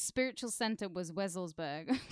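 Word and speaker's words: Wewelsburg: The castle, where's the Wevelsburg. – Wevelsburg. You know, it's because it's spelt with a wee-e, wee-e.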